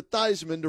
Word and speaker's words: Theismann: Changed it to Theismann to rhyme with Heisman, so it's Joe Theismann.